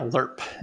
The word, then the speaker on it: LIRP: And that's, yeah, LIRP is the preferred pronunciation of that.